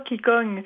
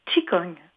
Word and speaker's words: Qigong: C'est que la prononciation du Qigong, ce n'est pas Qigong, c'est Qigong. – Qigong. – Oui, c'est une prononciation chinoise pour que les auditeurs puissent s'y reconnaître.